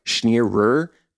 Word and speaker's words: Schenirer: And her last name, it's like Schenirer, but you kind of just roll the two R's together.